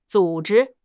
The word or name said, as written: zu3 zhi1